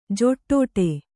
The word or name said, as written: ಜಿಡ್ಡು